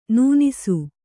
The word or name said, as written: ನೆನಸು